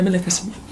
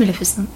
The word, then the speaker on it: Maleficent: Maleficent or Maleficent? No, Maleficent. – It's Maleficent, yeah.